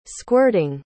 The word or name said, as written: squirting